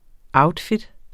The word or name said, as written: outfit